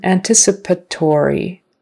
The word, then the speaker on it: anticipatory: Anticipatory. Anticipatory. It's on the second syllable again. Anticipatory. And kind of on the next to the last.